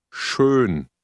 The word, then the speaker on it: schön: Schön. Schön. – If you have trouble pronouncing the O umlaut, think of the English word turn.